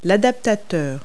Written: adapter

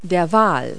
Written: whale

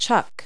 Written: chuck

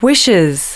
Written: wishes

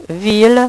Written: ville

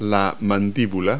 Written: mandible

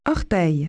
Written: toe

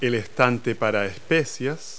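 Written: spice rack

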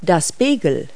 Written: bagel

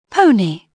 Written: pony

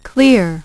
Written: clear

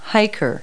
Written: hiker

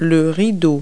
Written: curtain

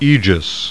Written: AEGIS

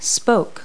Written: spoke

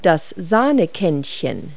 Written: creamer

